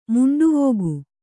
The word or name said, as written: ಮಂಡವಿಗೆ